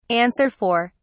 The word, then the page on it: answer for: British English